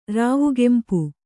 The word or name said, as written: ರಾವುಗೆಂಪು